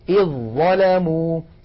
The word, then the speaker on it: إذ ظلموا: لاحظوا بأني أقول إذ، يعني ما عاد هناك في النطق ذال يا إخوة، على طول مباشرة إلى الظاء، إذ ظلموا.